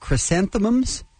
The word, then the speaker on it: chrysanthemums: Yesterday, they finished creating 9,000 bunches of chrysanthemums. Is that how you say it? – Mm-hmm. Yeah.